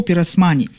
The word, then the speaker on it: Пиросмани: Я вот прям специально ударение прочитал, да, Нико Пиросмани.